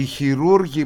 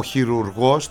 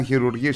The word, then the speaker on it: χειρουργός: Γιατί όταν είναι τασεούργος και τονίζονται στην παραλίγουσα, σημαίνει κάτι κακό. Λέμε κακούργος, πανούργος, ραδιούργος. Ο χειρουργός τονίζεται στη λίγουσα. Αυτοί για να το καταφέρουν ήταν χειρουργοί σίγουρα.